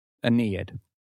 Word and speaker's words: Aeneid: Is that how you pronounce the Aeneid? – Aeneid.